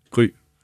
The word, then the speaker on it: Gry: Wie ist das auf Dänisch? Wie wird das ausgesprochen? – Gry.